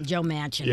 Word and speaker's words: Manchin: Mnuchin. – Joe Manchin? – Joe Manchin. Yeah. That's how you say his name, yes.